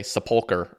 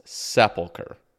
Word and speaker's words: Sepulcher: It's the stress is on the first syllable. It's Sepulcher, but.